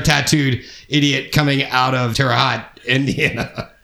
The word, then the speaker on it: Terre Haute: Is it Terre Haute or Terre Haute? – Terre Haute. Terre is so hot.